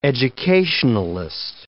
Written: educationalist

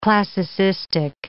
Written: classicistic

